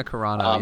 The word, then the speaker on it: Carano: Yeah, Gina Carano.